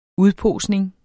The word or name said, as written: udposning